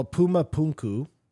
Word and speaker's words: Puma Punku: Well, Puma Punku, which is a...